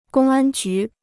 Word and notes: 公安局: gōng ān jú

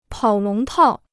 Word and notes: 跑龙套: pǎo lóng tào

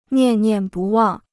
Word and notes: 念念不忘: niàn niàn bù wàng

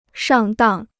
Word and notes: 上当: shàng dàng

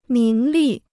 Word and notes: 名利: míng lì

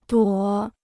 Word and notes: 朵: duǒ

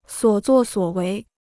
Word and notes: 所作所为: suǒ zuò suǒ wéi

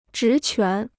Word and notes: 职权: zhí quán

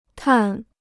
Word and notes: 碳: tàn

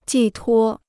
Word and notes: 寄托: jì tuō

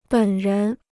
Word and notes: 本人: běn rén

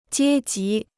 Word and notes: 阶级: jiē jí

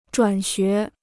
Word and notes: 转学: zhuǎn xué

